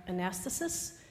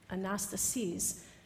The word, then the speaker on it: anastasis: The first one is called Praise the Name, and in the bulletin it might look like anastasis, but it actually is pronounced anastasis, and that word anastasis means resurrection.